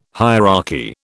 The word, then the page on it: hierarchy: British English